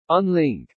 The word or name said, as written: unlink